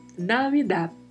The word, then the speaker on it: Navidad: Temos aí particularidade de pronúncia. Mais uma vez, V com leve som de B, temos falado bastante disso nos últimos podcasts. E ao final da palavra temos um D que é mudo, Navidad. Navidad.